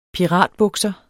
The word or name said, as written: PIRATBUKSER